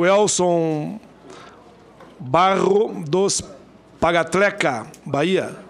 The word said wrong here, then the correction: Parateca: Pagatleca, daqui. Então tá aqui, Parateca. Tem um L a mais aqui, não é eu que pronunciei, não, tava aqui, ó. – É Barra do Parateca.